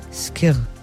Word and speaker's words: Skyr: Dieses Rezept geht gerade so viral, dass Kir sehr gefragt ist in den Supermärkten. Und bevor das hier ein Durcheinander gibt, ich habe unsere Aussprachedatenbank erstmal gefragt, wie wird das überhaupt richtig ausgesprochen? – Skir.